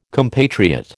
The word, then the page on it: compatriot: American English